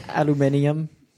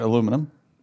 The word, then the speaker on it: aluminium: Aluminium. – Aluminium. That's right. Aluminum. – That's how they say it? – Yeah. There's like an extra U in there or something.